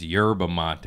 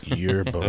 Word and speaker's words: yerba mate: I was calling it Herba Mate, but all my fans are like, it's Yerba Mate. I'm like, ooh. – Yerba Mate.